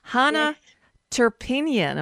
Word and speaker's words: Hanna Turpeinen: So joining me now from Vasa, Finland, Hanna Turpeinen.